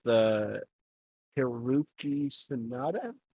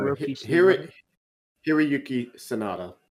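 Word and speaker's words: Hiroyuki Sanada: So, I watched a movie recently called Legend of the Eight Samurai with Hiroki Sanada. – I was about to ask if he was in that film. – Did I say that right? – No, Hiroki Sanada.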